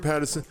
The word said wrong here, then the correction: Pattinson: Pattinson, something like that.